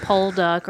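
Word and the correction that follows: Pokluda: or whatever you said over there, mispronouncing the gentleman's name. – It's Pakluda.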